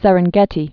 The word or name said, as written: Serengeti Plain